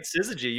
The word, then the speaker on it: Syzygy: I think I pronounced that right. – That's right. Syzygy. You got it.